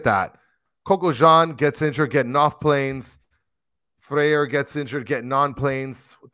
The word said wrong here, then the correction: Freire: Freire. Mike V says you pronounce it Freire.